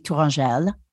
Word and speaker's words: Tourangelle: T O U R A N G E L L E. Well, if it were French, it'd be Tourangelle. – Okay, Tourangelle. that's how you that you pronounce it.